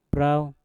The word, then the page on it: brauw: Mpur